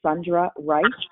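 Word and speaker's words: Sundra Ryce: It's Sundra Rice, SundraRyce.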